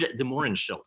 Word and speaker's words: de Mohrenschildt: One George de Mohrenschildt. Am I pronouncing that right? – Oh, yeah.